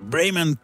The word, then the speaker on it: Bremen: Ja, zo diep zelfs dat een speciale Bremen Classic Collection is. Ik ga het in het Engels uitspreken ja. een beetje.